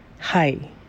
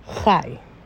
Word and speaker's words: Chai: Hai is the Hebrew word for life. It's pronounced like when you say hi, how you doing? And sometimes it could be Chai.